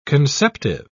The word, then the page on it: conceptive: British English